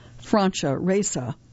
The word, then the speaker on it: Francia Raisa: Pardon me, that name is properly pronounced Francia Raisa.